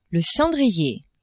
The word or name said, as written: cendrier